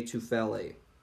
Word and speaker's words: Tufele: 37th overall pick, the Philadelphia Eagles select Jay Tufele. I think that's how you pronounce his last name. – Yeah, Tufele out of USC. Tufele, yeah.